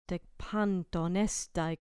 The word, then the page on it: dek panton: grec